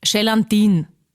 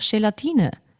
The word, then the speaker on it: Gelatine: Aber es gibt auch Menschen, die sagen zum Beispiel Gelatin. Und ich weiß schon, ich will jetzt auch nicht super gescheit oder obergescheit da daherreden, aber ist halt einfach falsch. Und sagt es mir, wenn ich irgendwas falsch ausspreche, aber man kann auch sagen, es ist einfach Gelatine.